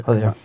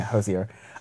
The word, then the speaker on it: Hozier: Is that how we pronounce it? – Hozier. – Yeah, Hozier.